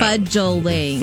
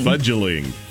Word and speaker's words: fudgeling: So just wanted to give you that term, and it is spelled F-U-D-G-E-L. And I had to really practice that, like fudge, like angel, right? – Yeah. – Fudgeling. – Fudgeling.